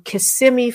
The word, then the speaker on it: Kissimmee: And this is part of the hype machine leading up to the Meekum auction in January in Kissimmee, Florida.